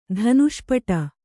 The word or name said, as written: ಧನುಷ್ಪಟ